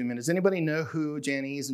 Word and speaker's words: Jannes: And then it ends with, Paul's buildup is, just as Jannes and Jambres, and yes, that's the proper way to say it,